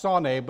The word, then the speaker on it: sane: It's pronounced sane, but it's sane, S-A-N-E, which is used 145 times, and it means to hate, to set against.